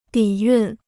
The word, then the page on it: 底蕴: dǐ yùn